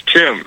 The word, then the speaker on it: Tim: Actually, it's Tim. It's Tim, T-I-M.